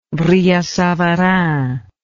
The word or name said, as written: brillat savarin